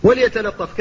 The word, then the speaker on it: وليتلطف: وليتلطف كذلك لا بد من التحفظ من تفخيم اللام في وليتلطف في سورة الكهف،